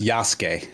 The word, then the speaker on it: Yasuke: I added Yasuki on Netflix to my watch list. – That is pronounced Yasuke.